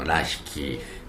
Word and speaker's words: LAHIKI: まあ、ラッキーさんとあのハワイ語の発音発音というかね表記ですと LAHIKI のラヒキ